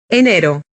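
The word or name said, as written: enero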